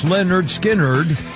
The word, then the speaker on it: Lynyrd Skynyrd: Lynyrd Skynyrd released their debut album, pronounced Lynyrd Skynyrd, as though people had to spell it out because it's spelled funny.